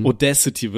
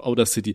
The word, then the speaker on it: Audacity: Ich glaube, Audacity wird das tatsächlich ausgesprochen, aber ich finde, das klingt richtig kacke, Alter, ich nenne das auch Outer City.